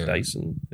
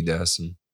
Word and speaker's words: Dassin: It's Dyson, isn't it? – Dyson. – I think. – It's spelled with an A, S-S-I-N, so I'm not sure. – Dassin? – Maybe Dassin. – We'll go with that.